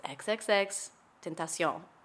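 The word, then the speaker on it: XXXTentacion: And he said, it's XXX Tentacion.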